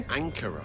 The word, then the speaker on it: Ankara: Ankara.